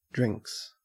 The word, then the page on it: drinks: Australian English